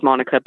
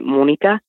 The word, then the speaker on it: Monica: My name is Monica, but they pronounce it Monica.